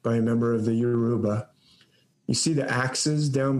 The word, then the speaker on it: Yoruba: This is a god, a very important god of the Yoruba nation, the Yoruba, or sometimes I've heard it pronounced like Jaruba.